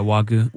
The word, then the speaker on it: Wagyu: Yeah, Wagyu.